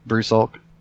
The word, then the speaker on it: Brusalk: And then I somehow ended up on Brusalk. It's sounding natural to me, of course. Doesn't prevent people from, well, for whatever reason, pronouncing my name like Brusalak or Brusilk or something.